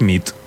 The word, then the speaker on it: nit: Y noche es nit. – Muy bien. Buena nit. – Buena nit, es verdad. – Buena nit.